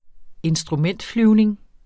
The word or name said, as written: instrumentflyvning